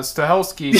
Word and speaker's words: Stahelski: Stahelski.